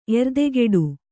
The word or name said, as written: ಎರ್ದೆಗಿಡು